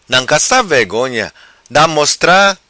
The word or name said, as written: nancassa vegonha